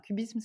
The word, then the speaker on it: QBism: Alors, cubisme, ça s'écrit Q-B-I-S-M, le Q pour quantum, et, et c'est l'acronyme en fait de quantum Bayesianism. Donc, c'est une interprétation qui est dite bayésienne de la mécanique quantique.